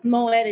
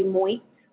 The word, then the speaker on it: Moitt: Yeah, think of Moitt as chandon. Some people say Moitt.